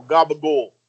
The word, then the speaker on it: gabagool: It's spelled capicola, but God forbid if you go in and say that, you got to go gabagool.